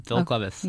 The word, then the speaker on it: Phil Cleves: Yep, Phil Cleves.